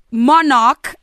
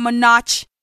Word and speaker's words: Monarch: Monarch is how you say it. It's not Monarch.